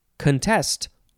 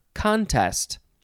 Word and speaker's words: contest: It is the word contest or contest. Emphasize either syllable. It's spelled C-O-N-T-E-S-T.